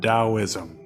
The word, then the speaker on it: Taoism: Always a little bit confusing because whether it's spelled two different ways, at least in English, one with a T at the beginning, one with a D, but is pronounced as though it has a D, Taoism.